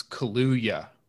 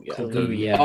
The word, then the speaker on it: Kaluuya: It's Kaluuya. So the two Kaluuya. U's Kaluuya. Are pronounced with a U. – Mm. – Daniel Kaluuya.